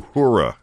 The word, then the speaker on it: Uhura: Uhura.